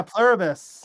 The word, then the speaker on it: Pluribus: I just got done watching Pluribus. I can never say the word right. – Yeah, Pluribus. – Pluribus. Oh man, too many syllables.